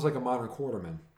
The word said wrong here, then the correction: Quatermain: Oh, it's Quatermain. – Oh, well, that's how you pronounce it. Oh, screw me. – No, it's not spelled. It's not pronounced how it's spelled, so I totally get you.